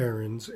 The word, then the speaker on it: Ahrens: David Ahrens, A-H-R-E-N-S.